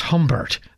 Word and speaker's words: Gombert: But we just have time for a final motet for Lent, Mort et Fortuna, Death and Luck, by the Flemish composer Nicholas Humbert. That's spelled G-O-M-B-E-R-T.